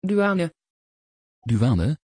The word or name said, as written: Duane